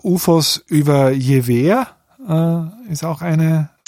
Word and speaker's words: Jever: Die meisten Deutschen sprechen das falsch aus, weil sie den Ortsnamen nur aus der Bierwerbung kennen, wo er falsch gesagt wird. Es ist Jever, ein schöner Ort in Friesland.